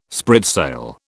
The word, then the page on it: spritsail: British English